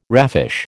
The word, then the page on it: raffish: American English